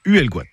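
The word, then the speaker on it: Huelgoat: Nous sommes dispersés partout dans ce bois haut, d'où le nom breton Uelgoat, le haut bois, qui se prononce Uelgoat.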